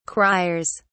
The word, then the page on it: criers: British English